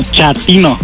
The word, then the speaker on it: chatino: Chapino. – ¿Chapino? – No, chatino. – Es como chato, ¿verdad? Chatino. – Sí, asunero, como chato, chatino.